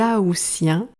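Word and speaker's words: aoûtiens: Ainsi, on parle des juilletistes pour les personnes qui partent en juillet et des aoussiens, le T est prononcé S, pour les personnes qui partent en août et tu peux prononcer ou ou alors out. Les deux prononciations existent.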